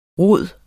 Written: rod

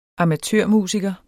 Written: amatørmusiker

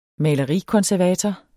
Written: malerikonservator

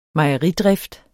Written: mejeridrift

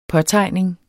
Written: påtegning

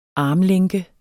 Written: armlænke

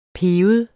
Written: piber

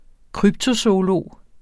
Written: kryptozoolog

